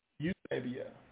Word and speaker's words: Eusebia: Eusebia. That's how it's pronounced. Eusebia. There's a historian out there. Eusebius, but Eusebia.